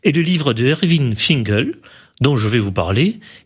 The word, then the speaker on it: Finkel: Irving Finkel, je le dis bien, celui-ci.